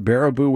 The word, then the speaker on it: Baraboo: Is that how you pronounce it? Baraboo, Wisconsin? – Yes.